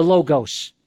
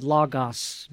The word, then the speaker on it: Logos: Now, today I want to talk about the fact that Jesus is the Logos, or some pronounce it Logos, but Logos seems to be more accurate.